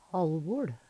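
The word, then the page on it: HalvoL: Numedalsmål; Veggli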